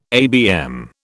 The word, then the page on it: ABM: British English